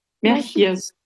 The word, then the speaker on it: Merchiers: Ja, und der Name wird dann ausgesprochen? – Merchiers. Ja, wie Sie wollen.